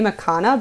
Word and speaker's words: Erchana: So soon I will change that that handle to my name, Erchana.